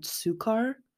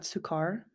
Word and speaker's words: Sukkar: But in one of these articles I read about her, they brought up her being friends with Matt Sukar. I think is how you say his name. Matt Sukar. S-U-K-K-A-R is how you spell his last name.